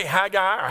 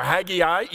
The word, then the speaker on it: Haggai: Now, you can say Haggai or Haggai. Either one of those two pronunciations are acceptable.